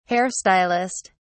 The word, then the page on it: hair stylist: British English